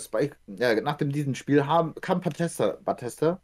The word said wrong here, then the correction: Bethesda: Betesta, Bethesda,